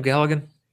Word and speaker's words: Galligan: Is that how you pronounce his last name, Galligan? – Yeah.